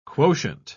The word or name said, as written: quotient